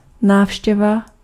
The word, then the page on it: bezoek: [be.zuk]; /bə.ˈzuk/